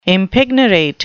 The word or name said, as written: impignorate